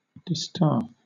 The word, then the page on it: distaff: British English